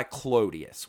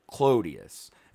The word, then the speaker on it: Clodius: Well, that's what we're going to call him from now on, because Claudius was the like fancy Roman pronunciation of it. He wanted to be a man of the people, so he changed it to the way the plebs pronounced it and said Clodius.